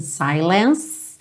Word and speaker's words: silence: In silence. Termina nesse som do C, não vai pro E, o E é mudo, ok?